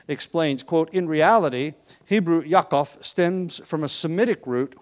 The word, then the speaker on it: Yaakov: The name Jacob is, the Hebrew name is Yaakov, or Yaakov, depending on how you want to pronounce that final consonant, but it's generally pronounced Yaakov, or Yaakov.